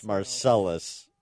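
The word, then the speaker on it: Marseilles: Or Marseille is pronounced Marcellus with respect to the town here.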